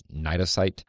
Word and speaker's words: cnidocyte: which I guess are called So it's spelled with a C, but it's pronounced cnidocyte.